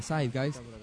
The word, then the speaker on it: Zeitgeist: El documental se llama, se escribe Zeitgeist, se pronuncia Zeitgeist.